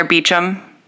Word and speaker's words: Beauchamp: First episode, if you've never seen Outlander, we follow Frank and Claire Beecham. – yeah, goofiest pronunciation like Beauchamp. Of Beauchamp that I've ever seen in my entire life.